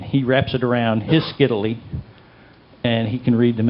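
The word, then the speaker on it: scytale: The oldest known cryptography device is called a Skiddly. That's the way Wikipedia has it pronounced. It sounds kind of odd to me, but we'll stick with the Wikipedia pronunciation.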